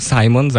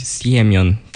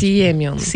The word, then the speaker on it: Semion: Ogólnie, jeżeli chodzi o zagranicę, to mówią Simon zawsze na niego. A tak, ale poprawnie się powinno niby powiedzieć Siemion, tak mocno zaakcentować. – Siemion.